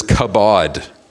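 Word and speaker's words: kabod (כבוד): Not that it really is helpful for most of us who speak English to know the Hebrew, but the Hebrew word is kabod. We could, again, it uses Hebrew letters, so it doesn't really help to tell you how it's spelt, but in our English translation of the Hebrew, it would be K-A-B-A-D, or something like that, kabod.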